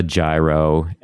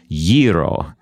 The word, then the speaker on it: gyro: We're going to like give them a gyro and slap them on the back and welcome to the festival. But just like Paniiti, it's pronounced gyro. Can you say that? – Gyro.